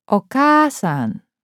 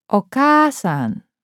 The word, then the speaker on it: お母さん: A long vowel is simply a repetition of the same vowel sound. Follow along in your book and listen to the following words and note the elongated vowel. – お母さんお母さんゆゆお姉さんお姉さん大阪大阪